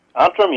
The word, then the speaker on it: Antrim: but it's looks like it's pronounced Antrim, Northern Ireland. – Antrim, yes.